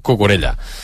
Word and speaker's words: Cucurella: Un dels meus hobbies preferits és com pronuncien els anglesos Cucurella. – Cucurella. – Cucurella. Oh, Cucurella. – Sí. Anem a provocar. – Les dobles de la se'ls compliquen.